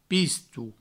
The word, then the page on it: pisto: pistou